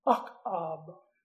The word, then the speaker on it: Ahab: Ahab, actually, we in the English-speaking world call him Ahab. The Hebrew is pronounced Akab, which means friend of the father.